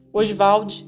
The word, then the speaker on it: Oswald: ele gostava de pronunciar o nome Oswald dessa maneira, Oswald de Andrade.